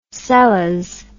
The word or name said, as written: seles